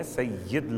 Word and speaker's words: سید: ھذا یہ والا بیٹا، سید، تلفظ میں فرق اس لیے محسوس ہوگا آپ کو کہ سید جو ہم پڑھتے ہیں وہ اردو کے لحاظ سے اصل تلفظ یہ نہیں ہے، سید لفظ ہے، سردار.